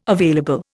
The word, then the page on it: available: Indian